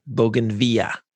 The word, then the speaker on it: bougainvillea: My opinion on bougainvillea is substantive. – I don't even know if you're pronouncing that right. – Bougainvillea. – There you go. Now you're doing it.